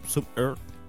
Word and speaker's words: Zoom Earth: Zoom Earth. Suena como una especie de erupto.